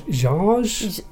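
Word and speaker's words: Książ: Yeah, well, it's spelt like K-S-I-A-Z. – Oh, I have no idea. – But I looked up the pronunciation. Apparently, it's sort of Jarge.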